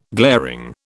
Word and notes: glaring: British English

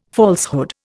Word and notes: falsehood: Indian

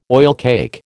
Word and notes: oilcake: American English

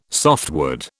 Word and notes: softwood: British English

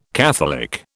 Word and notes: catholic: British English

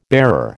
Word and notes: bearer: American English

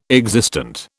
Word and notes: existent: British English

